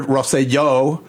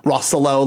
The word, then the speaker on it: Rosselló: Just for the record, Rosselló, mm-hmm. you say his name like yo, yo, yo at the end, mm-hmm. not Rosselló like Rolo. – Because the accent's on the last.